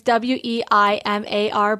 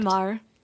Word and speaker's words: Weimar: I now want to experience my other fascination of a historical time in Berlin, which is, I'm probably saying it wrong, Weimar. It's Weimar, Berlin. – I don't see why Weimar. That would be wrong. Weimar seems correct. – Weimar.